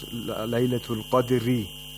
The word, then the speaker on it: Laylatul Qadri: If you don't stop, it's Laylatul Qadri, so you have to pronounce it with thinness if you continue.